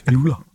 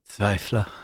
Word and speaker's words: Tvivler: Tvivler. hvad, hvad, hvad er den mærkeligste udtale af jeres bandnavn, I har mødt? – Zweifler.